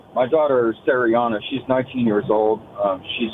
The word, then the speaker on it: Cieriana: C-I-E-R-I-A-N-A, Sariana. – Sariana.